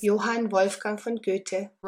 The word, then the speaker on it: Goethe: Is that how you pronounce it? G-O-E-T-H-E? Goethe? – Sure. Goethe.